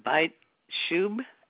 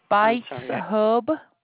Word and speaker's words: Bites Hub: Okay, Byte Shub, S H U B, is that what how you would pronounce it? – It's Byte Hub. – It's what, hon? – Bites Hub. – Oh, Bites. It's B-I-T-E-S Hub.